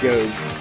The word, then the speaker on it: Job: Nobody seems to realize that it's pronounced Job.